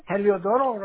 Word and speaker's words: Heliodoro: My name, Heliodoro Ramirez. – Oh, Heliodoro. Can you spell that for me? – H-E-L-I-O-D-O-R-O.